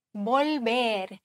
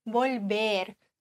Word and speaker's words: volver: Volver. V wird im Castellano ähnlich wie ein B ausgesprochen, also sehr weich. Volver, zurückkehren.